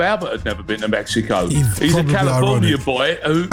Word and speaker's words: Mexico: When you get there, don't pronounce the X the way that we do. Just say Mexico.